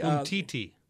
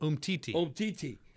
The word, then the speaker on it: Umtiti: Imatiti, whatever. He was born in Cameroon. And then Bakbe. – Umtiti. – Yeah, how you say it? – Umtiti.